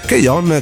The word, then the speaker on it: K-On: Keion, che stavamo dicendo, eh, che si scrive poi K-On, perché molti adesso magari eh, sentendo questo nome non fanno riferimento al, all'anime in sé. È quella serie che si chiama appunto K-On, ma eh, si scrive K-O-N e ha quelle protagoniste molto pucciose, molto carine che eh, suonano, fanno parte di una band scolastica.